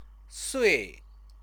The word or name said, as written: sui